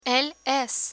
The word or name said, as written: el es